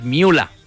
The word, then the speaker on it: Müller: La gente dice Thomas Müller. – Okay. – Müller. Es Thomas Müller, no. Porque el ER en alemán se pronuncia como una A.